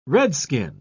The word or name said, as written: redskin